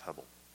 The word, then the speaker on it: hebel: A key word in the book is the word hebel, H-E-B-E-L. And I had to do the Google how to pronounce hebel. It's hebel.